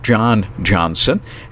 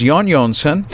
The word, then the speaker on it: Jón Jónsson: It looks like John Johnson, and we would pronounce that, but they pronounce J's in Iceland as a Y sound. So it's Jón Jónsson, and just a delightful, delightful gentleman.